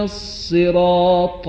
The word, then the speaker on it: الصراط: نمازگزار باید مراقب باشه طاء در صراط رو مانند طاء منقوط تلفظ نکنه. میخوانم عبارت سوره حمد رو: اهدنا الصراط المستقيم صراط الذين انعمت عليهم، به این صورت.